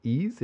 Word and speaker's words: Ehasz: I think his last name is pronounced Ease. It's Ehasz.